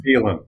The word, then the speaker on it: Phelan: Phelan.